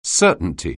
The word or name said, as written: certainty